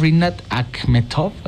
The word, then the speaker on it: Rinat Akhmetov: Y ahora regresando justamente, y ustedes se preguntarán, ¿quién es Rinat Akhmetov? ¿Así lo dije bien? – Sí, justo.